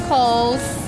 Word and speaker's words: Coles: Okay, Tiffany Coles. – How do you spell it? – T-I-F-F-A-M-Y. – Okay. – C-O-L-E-S.